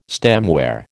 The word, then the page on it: stemware: American English